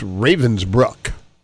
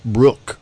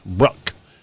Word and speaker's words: Ravensbrück: And the liberation of, I guess I've been pronouncing it wrong. I think it's Ravensbrück. Right? Not Brook. Bruck, I believe.